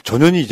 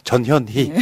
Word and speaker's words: 전현희: 그나마 권익위 위원장이 대차게 잘 싸우고 어제 기자회견도 보니까 사람들이 다 와가지고 전현이 이제 대선 나고 됐어. 그 정도 대가 있어야지. – 발음 정확히. – 전현희.